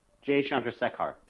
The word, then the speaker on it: Jay Chandrasekhar: And they were talking about when they were kids in middle school, the Jay Shen Cressidar, I'm not going to say his name right, Jay Chandrasekhar.